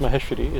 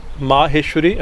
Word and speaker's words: Maheshwari: One is Mahi, Mah- Maheshwari and Maheshwari, right? One with a short A and with a long A. And usually it was Maha, like Maha Kali, Maha Saraswati, right? It's ma, the, the, the long A is second, Maha, right? But here it's Maha, Maheshwari. So I've been investigating what's the,